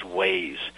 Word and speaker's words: Waze: I was driving up 81 yesterday trying to get home from back here from the mountains, and I was I've always pronounced this WASI, but I think the proper name is Waze.